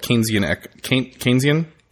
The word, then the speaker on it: Keynesian: K-E-Y-N-S-I-A-N-S. – Really? – Keynesian. – That's not how I spelled it at all. – Like the word key in the first part.